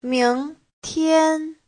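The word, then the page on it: 明天: ming2tian1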